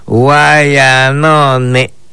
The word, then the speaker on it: Guayanone: Y en maya lo pronunciamos de esta manera: Guayanone. Escúchelo, Guayanone.